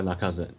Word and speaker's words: Lacazette: Lacazette? I don't know. – Alexander Lacazette.